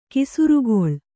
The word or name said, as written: ಕೆಸರಕಲ್ಲು